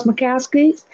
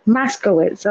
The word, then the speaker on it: Moscowitz: His name is Charles Moscowitz. And I hope I'm pronouncing your name correctly. And if I'm not, I do apologize. – Let me correct you. It's Moscowitz. – Moscowitz.